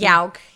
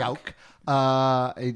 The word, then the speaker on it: Yauch: How do you pronounce the last Yauch. – Name? Yauch.